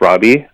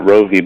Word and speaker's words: Rovi: Well, I get that a lot, and I guess the long story of it is, I was born in the Philippines, and my name is spelled with a Filipino V. And back home, it's pronounced Robbie. That's what my parents have always called me. But it's a little hard to explain that it's not Rovi.